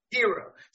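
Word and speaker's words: Herro: Speaking of Heat, what does Jimmy Butler, who's playing lights out right now, and Tyler Hero, is that how you say it? Hero? – Hero.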